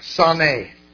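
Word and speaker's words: saneh (שָׂנֵא): Okay, saneh. Pronounced like that, okay?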